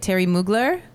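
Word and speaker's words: Thierry Mugler: Because basically, so yeah, it's this interview where she talks about the like Terry, how do you say it? Terry Mugler? – Yeah.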